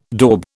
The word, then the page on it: daub: British English